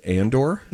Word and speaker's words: Andor: their latest Star Wars show called Andor, is going to air on ABC, on FX, on Freeform, and Hulu over Thanksgiving weekend.